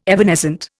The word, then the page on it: evanescent: Indian